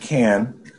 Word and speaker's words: Cannes: It's pronounced Can.